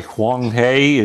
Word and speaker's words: Huang He: Next one, I can't pronounce it. H-U-A-N-G-H-E floods. It's probably Huang He.